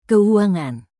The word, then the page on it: keuangan: indonesian